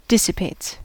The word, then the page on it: dissipates: American English